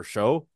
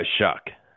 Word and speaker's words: Shough: And then Tyler Shough or Show. – Shuck. is actually how that's Really? – pronunciated.